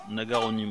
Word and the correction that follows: Naganori: moi j'ai noté. – bon, Oui, c'est ça, c'est Naga quelque chose. Un petit vieux. Euh, Naganori, voilà.